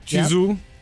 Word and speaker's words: Chizu: Chizu.